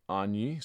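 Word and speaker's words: Agni (Αγνή): The first word is Agni.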